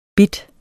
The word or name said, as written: bit